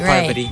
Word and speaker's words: Parvati: Parvati. – Parvati. Parvati. – Parvati. – Parvati ba? – That's how they say it. Parvati.